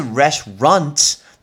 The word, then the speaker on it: restaurant: 特别要提醒大家，那个 restroom 这个字，好多人餐厅会念成 restroom 等等，餐厅是 restaurant，你要把那个 runt 把它给念出来。